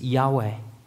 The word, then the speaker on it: Yahweh: It's a name that in the Hebrew we would really pronounce as Yahweh,